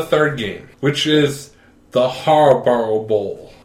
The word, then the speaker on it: Harbaugh: John Harbaugh versus Jim Harbaugh. Brother versus brother. – How do you say his name again? – Harbaugh. H-A-R-B. – Harbaugh. – Right there. Right there. It's H-A-R-B-A-U-G-H. Harbaugh.